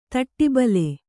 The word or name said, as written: ತಾಟಿಬೆಲ್ಲ